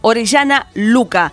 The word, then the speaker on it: Orellana Luca: Suena bien cacofónicamente, porque son dos apellidos con acentuación grave. Orellana Luca.